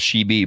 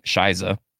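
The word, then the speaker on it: Scheiße: I at first thought it was She Be, but it's actually pronounced how she sings it in the song, Shiza.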